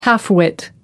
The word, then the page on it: half-wit: American English